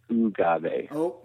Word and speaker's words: Ogave: And just for clarification, it's Ogave. – We made up a word that it doesn't adhere to any language pronunciation principles in its entirety. So sometimes people need a little assistance in pronouncing it correctly.